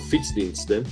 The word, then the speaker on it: Fietsdiensten: And this company is called Fietsdiensten. I hope I'm saying it right. – Yeah, you say it perfectly right.